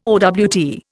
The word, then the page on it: owt: Indian